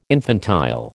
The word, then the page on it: infantile: American English